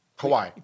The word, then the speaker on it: Kawhi: Kawhi.